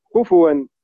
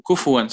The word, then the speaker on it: كفوا: أه دكتور، بالنسبة لدولة مصر حضرتك عندنا المصحف طبعا حفص وكده، فكلمة بننطقها كفوا، إن عندنا في حفص الفاء عليها ضمة، كفوا أحد. – طيب حتى كفوا صحيحة.